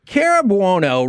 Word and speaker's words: Cara Buono: Cara Buono, right here on 105.3 The Fan. How did we do with that pronunciation? – Hi. Perfect.